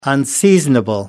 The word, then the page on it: unseasonable: American English